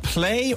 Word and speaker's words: Plai: Tell us about, did you say it was, you're pronouncing it Play or P-L-A-I? How are you pronouncing this? – I'm pronouncing it Play, but it is spelled P-L-A-I because okay.